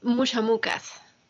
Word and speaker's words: Mushamukas: Eh, a ver, yo quiero que platiques también sobre Muchamucas. ¿Lo dije bien? Sí, Perfecto.